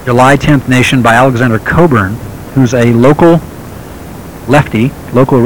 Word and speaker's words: Cockburn: I see Robert showing me a tape by Alexander Coburn, and that Coburn is spelled C-O-C-K-B-U-R-N, Cockburn, but I believe that the pronunciation is Coburn.